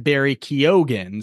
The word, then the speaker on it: Barry Keoghan: who told me that we were pronouncing Barry Keoghan's name wrong. It is, in fact, Barry Keoghan. So I hope I am saying that correct now.